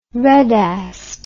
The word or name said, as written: redassed